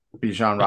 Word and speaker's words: Bijan: How do you say this? Bijan Robinson? – Bijan Robinson from Texas? – Yeah.